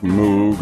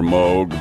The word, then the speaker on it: Moog: You know, I don't want to say anything bad about Moog or Moog, but when it comes to the best keyboards, there's no guesswork.